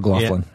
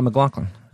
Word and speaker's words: MacLachlan: MacLachlan, is that how you say his name, or Glaughlin? – Yeah. Yeah. – MacLachlan? – Cares? Who cares? – It's Kyle MacLachlan.